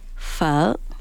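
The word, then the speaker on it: phở: So the two other pronunciations are like the anglicized, westernized, because it's, you know, it's P-H-O, right? Mm-hmm. But Vietnamese is polytonal, so we have these four tones. So depending on the accents on top, that's how you would pronounce it. So it has a question mark on top, and it's like a sound.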